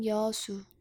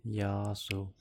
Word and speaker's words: Yeosu: I'm from Yeosu. – Yeosu. – Mm-hmm. Y-E-O-S-U. – Yeosu. – Mm-hmm. – Where were you born then? – Yeosu.